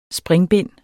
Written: springbind

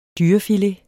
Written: dyrefilet

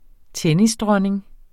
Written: tennisdronning

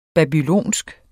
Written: babylonisk

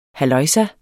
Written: halløjsa

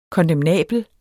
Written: kondemnabel

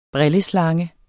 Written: brilleslange

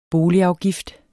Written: boligafgift

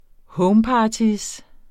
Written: homeparty

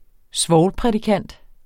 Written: svovlprædikant

